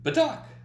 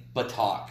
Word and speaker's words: Batak: It sounded more familiar to me when I sounded it with a soft A. – A soft A, okay. Yeah. Yeah, I wasn't sure how to say it, so. – I think Batak, it just sounded like, because it's Indonesian. – It sounds. – And a lot of provinces in Indonesia have soft A's.